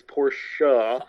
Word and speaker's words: Porsche: He contracted Ferdinand Porsche, for those who can't pronounce it correctly, it's Porsche.